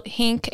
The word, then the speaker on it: Hink: My name is spelled Hink, H-I-N-K, on every pleading and everything we file. And then she always calls me Sarah Hicks.